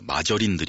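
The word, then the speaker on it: margarine: 우리 고스에서 다들 많이들 공감하셨던 얘기가 그 80년대에서 90년대까지 이어졌던 그 마저린 드립 아니겠어요? 마저린? 버터는 동물성 지방이니까 몸에 나쁘다. 마가린. 그쪽 발음으로는 마저린이라면서요.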